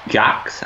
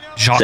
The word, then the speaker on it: Jacques: And doesn't he say Jax? How do you say it? Quebec Jax. – Jacques.